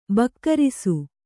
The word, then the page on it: ಬಿಗುರಿಸು: bigurisu; Kannada